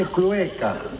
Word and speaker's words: clueca: Profesor, se dice clueca y no culeca.